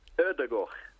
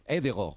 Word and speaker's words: Ødegaard: Escucha este periodista noruego. Escucha. – Vale. – Es Martin Ødegaard. Sí. – Ya lo sabes. Ødegaard o algo parecido.